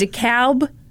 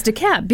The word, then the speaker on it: DeKalb: You said DeKalb. Yeah. Illinois. Yeah. And I was like, it's DeKalb because yeah.